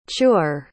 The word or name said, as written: chewer